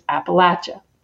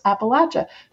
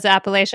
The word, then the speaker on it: Appalachia: The way we see, the way we say it is Appalachia, 100%. That's how we say, that's who we are. And I find it so interesting that even 100 miles outside of here, people don't know how we pronounce Appalachia.